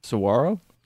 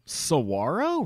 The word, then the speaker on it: saguaro: Is that how you pronounce that word? – Saguaro. – Saguaro, really? – Yeah, the, like the cact, like the iconic cactus. – The icon, the iconic saguaro cactus.